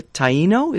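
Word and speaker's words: Taíno: And so when he did land on those islands, he encountered the native people there who are called the Taino, is that correctly? – Some of them, yeah. – Pronounced? – Yes.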